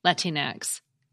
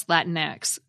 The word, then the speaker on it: Latinx: Well, Latinx is usually pronounced the way you've been hearing me say it, Latinx, and also more rarely as Latinx, according to Merriam-Webster and a video by the American Pronunciation Guide.